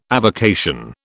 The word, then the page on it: avocation: British English